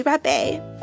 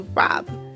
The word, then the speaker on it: rabe: I was the wealthy aunt, Miss Broccoli Rabe, but it's actually pronounced Broccoli Rob, like rob a bank.